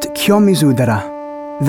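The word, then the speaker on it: Kiyomizu-dera: It's pronounced kyomizudera. That's kyomizudera.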